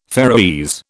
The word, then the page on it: Faeroese: British English